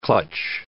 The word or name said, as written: clutch